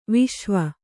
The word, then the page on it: ವಿಷುವ: viṣuva